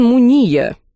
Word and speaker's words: Муния: Покачал он головой, глядя на меня, но ответил, делая ударение в своей фамилии на последний слог. «Господин Муния,